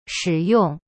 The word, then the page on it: 使用: shi yong